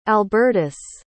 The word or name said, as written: albertus